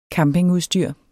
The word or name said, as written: campingudstyr